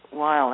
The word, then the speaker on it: Weil: Simone Weil, I think you pronounce her name, Weil.